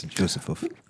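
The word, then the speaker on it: Józefów: And so the number of there are a bunch of huge massacres, Juzefuf. Is that how you say it? – Yes, Juzefuf.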